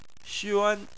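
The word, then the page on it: xuan: tone 1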